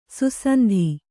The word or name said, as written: ಸುಸಂಧಿ